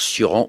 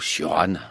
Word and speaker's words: Cioran: Alors, Cioran ou Cioran, on peut le prononcer des deux manières.